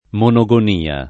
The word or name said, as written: monogonia